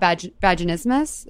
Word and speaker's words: vaginismus: about vaginismus.